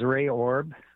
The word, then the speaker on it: Ray Orb: My name is Ray Orb, and it's spelled R-A-Y-O-R-B, and I am the Reeve of the rural municipality of Sherwood.